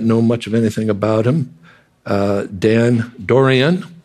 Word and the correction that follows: Doriani: Not even sure if I'm pronouncing the last name correctly. Dan Doriani it would be.